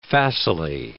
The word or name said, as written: facilely